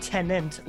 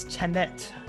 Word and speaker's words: Tenet: It's not tenant like a tenant in a apartment building. It's Tenet, T-E-N-E-T.